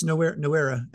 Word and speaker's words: Noura: Noera, I think is how you pronounce it. – I can't remember it. – His last name. – Yeah, it's Noera, N-O-U-R-A.